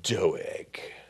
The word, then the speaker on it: Doeg: Doeg, the Edomite, who was set, but we have to say it correctly, Doeg.